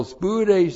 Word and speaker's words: spoude: S-P-O-U-D, long E, spoude.